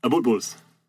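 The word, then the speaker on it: Abutbul: Sorry, Abutbuls, am I saying that right? – Abutbuls.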